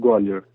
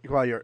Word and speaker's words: Gwalior: I'm from Gwalior. – Gwalior.